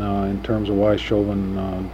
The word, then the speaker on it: Chauvin: Yeah, I think that's what Bryn Campbell called him, Chauvin. That's the way he pronounced it, the country flair.